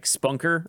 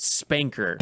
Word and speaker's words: SPNKr: I've always thought it was like Spunker or something. – Spanker. – Yeah, but they've actually pronounced it. I think this is the first game where they pronounced it Spanker.